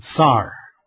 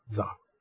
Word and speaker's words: sir: But the way they pronounce it is sar, like the Russian tsar. So, that's just their pronunciation. They spell it S-I-R, but they pronounce it C-Z-A-R, tsar.